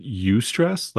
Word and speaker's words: eustress: One is called, I would pronounce it eustress, like eudaimonia, right? – Mm. – So E-U-S-T-R-E-S-S, eustress and distress.